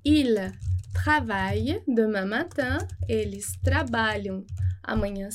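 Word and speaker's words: ils travaillent: E a gente não vai pronunciar o ENT. Então, o ENT é mudo, ele não vai ter som. Il travaille demain matin, il travaille. Nada de il travaillante, tá?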